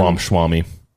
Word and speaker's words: Ramaswamy: But some Republicans, such as presidential candidate, is it Vivek Ramaswamy? I can't pronounce that name very well. I could give him Ramaswamy. – Sure. Thank you.